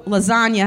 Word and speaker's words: lasagna: Okay, so lasagna has a silent G in it. G is short for gangster. So, like, gangsters move in silence like lasagna. Because of the silent G.